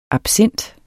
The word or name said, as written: absint